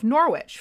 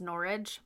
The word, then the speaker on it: Norwich: Alice's mother smelled something fishy, so she deposited the land deeds with the mayor of Norwich for safekeeping and freed Alice from her prison. – It's pronounced Norwich, but... – Oh, it's Norwich, Norwich.